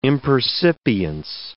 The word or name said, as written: impercipience